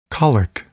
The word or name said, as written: colic